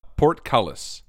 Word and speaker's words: portcullis: Portcullis. Portcullis. – So, and the emphasis is on the cull.